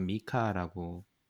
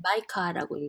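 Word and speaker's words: MICA: 미카라고 미카라고 읽는 게 맞나요, 대표님? – 마이카라고 읽어요.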